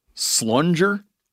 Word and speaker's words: slunger: A big buck is a slunger? – You know, it's the G being pronounced the same as in giant.